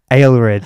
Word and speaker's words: Aelred: Aelred.